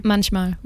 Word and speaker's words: manchmal: Yeah, and Manche Mal. I can't not say it like French. I'm sorry. – I, I, don't even know if it's in French actually. Ah, okay. it's in German. It's German. Ah, okay. Manchmal. Manchmal.